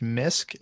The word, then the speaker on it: MYYSK: I am going to recommend a song by, I believe it's pronounced Mysk. It's MYYSK.